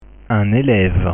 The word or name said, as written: un eleve